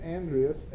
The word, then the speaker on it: Andreas: It's A period Andreas, A-N-D-R-E-A-S, Matossian, M-A-T-O-E-S-I-A-N.